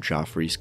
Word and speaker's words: Joffrey's: And that is, you know, a friend of ours, Nick Salcedo, recommended splitting the freshly brewed Joffrey's coffee, said it right this time, Hawaiian Kona blend in a French press pot.